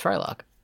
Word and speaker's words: Frylock: His name rhymes with Mike... – Hawk. Yeah. You could call him Livewire. It's Frylock.